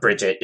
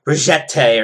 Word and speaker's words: Brigitte: I always thought, so her name is, oh gosh, it's like, it's not Bridget. It's like Brigitte or something. They pronounce it strange.